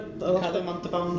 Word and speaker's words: Ricardo Montalban: Ricardo Montalban.